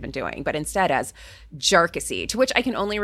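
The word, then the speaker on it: Jarkesy: And by the way, it's pronounced Jarkissi. Not a number of other ways that it's been pronounced by many.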